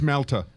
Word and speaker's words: Malta: Is that pronounced Malta? – It's Malta.